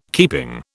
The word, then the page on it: keeping: British English